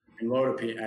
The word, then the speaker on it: amlodipine: Am Amlada Pink, Am Amlada Pink, and uh, Amlodipine. I think it's pronounced amlodipine, but yep. – Okay, amlodipine.